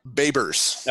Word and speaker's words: Babers: Did you say his name was Rod Diapers? – Babers. – Oh, Babers. B-A-B-E-R-S.